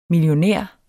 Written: millionær

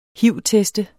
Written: hiv-test

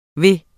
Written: ved

